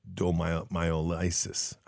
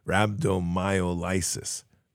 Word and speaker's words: rhabdomyolysis: The dehydration caused a condition called Rabdo myolysis, rhabdomyolysis, I think. Oh, here it is. Pronounced rhabdomol, rhabdomyolysis.